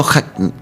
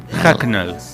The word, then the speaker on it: Hucknall: Pronunciame de nuevo Hucknall. Hucknall. H-U-C-K-N-A-L-L. Se escribe Hucknall y se dice Hucknall.